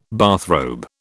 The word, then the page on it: bathrobe: British English